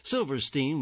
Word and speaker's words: Silverstein: Silverstein, with the last syllable pronounced Steen, even though it's spelled S-T-E-I-N.